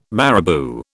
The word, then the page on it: marabou: British English